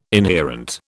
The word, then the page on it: inherent: British English